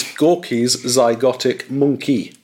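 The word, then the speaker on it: Gorky's Zygotic Mynci: I have got Gorky Zygotic Mine. I can't pronounce that. – Absolutely brilliant. Okay, all right. What a brilliant first choice. This is Gorky's Zygotic Monkey.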